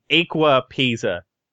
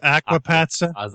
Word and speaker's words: Aqua Pazza: We have God Eve Geek, Injustice, Gods Among Us, Killer Instinct, and Aqua Pazza. – It's actually pronounced aquapazza. – I know. – Aquapasta? – Close enough.